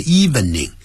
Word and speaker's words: evening: 别忘了，不要念成 evening。 Evening 只有两个音节 Evening 啊，这样念实际上是这样。